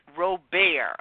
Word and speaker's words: Robert: It is pronounced Robert, like Stephen Colbert.